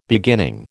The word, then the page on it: beginning: American English